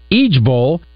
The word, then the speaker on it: IJBOL: Now there's a new one the internet is pushing, IJBOL. Pronounced Ejbol, it means I just burst out laughing.